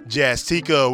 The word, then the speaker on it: Jazztica: You know I had to do it just as much as I got to tell you about Jazztica, which rhymes with Eureka, calling all who think, make, write, or speak creatively.